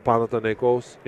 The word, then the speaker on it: Panathinaikos: Uh, but yeah, I paid for Panathinaikos in, in Athens.